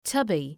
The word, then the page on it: tubby: ‘tʌbı